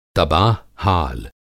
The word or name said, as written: तबाह-हाल